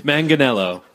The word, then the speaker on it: Manganiello: Manganiello. – Okay, Joe Manganiello.